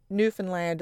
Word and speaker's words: Newfoundland: It's never Lund. It's always land. – Right. And to be fair to everybody who does mispronounce it, I mean, most countries or places that end in L-A-N-D is pronounced Lund, right? – Yes. – It's easy to understand a mistake. – Yes, it's always Lund. But for whatever reason, Newfoundland is always the stress on the land.